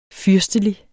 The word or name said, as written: fyrstelig